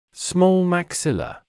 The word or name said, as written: small maxilla